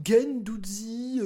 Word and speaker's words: Guendouzi: Euh, ils disent non, je me permets de confirmer, on dit Gendouzi, je confirme. Tu,